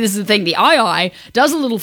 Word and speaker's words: aye-aye: There is an animal native to the island of Madagascar called an aye-aye. And, you know, like aye-aye captain, and that's how it's spelled.